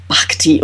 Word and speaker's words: bhakti: Bhakti. Oh, B-H-A-K-T-I. – Yep. B-H-A-K-T-I. – I suspect it's probably a long A as well. It's probably and it's an aspirated B, so it's probably bhakti.